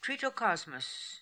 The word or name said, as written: Tritocosmos